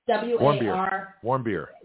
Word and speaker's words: Warmbier: His name was, I don't know if I'm pronouncing the last name right, so I'm sorry, but it's Otto Warmbier. – Warmbier. – W-A-R-M-B-I-E-R. W-A-R-M-B-I-E-R. – Yeah, warm beer. Yeah, I think it's German. Warm beer.